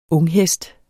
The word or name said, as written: unghest